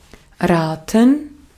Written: raten

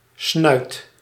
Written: trompe

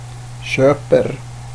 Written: köper

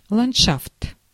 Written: paysage

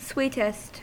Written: sweetest

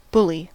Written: bully

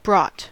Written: brought

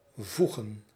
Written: voegen

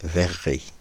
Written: verrerie

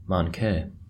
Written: manqué

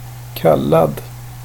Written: kallad

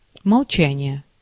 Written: молчание

